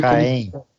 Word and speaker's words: Caen: Isso escreve Caen, mas como é que fala em francês? Não deve saber. – Caen.